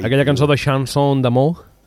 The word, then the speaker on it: chanson d'amour: Jo és que jo francès no sé si ho he pronunciat bé, la Mercè Sandriu. – Chanson d'amour. – Chanson d'amour. – D'amour. – Ah. – D'amour. – Très bien.